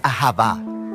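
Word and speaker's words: ahavá (אהבה): Se pronuncia ahavá.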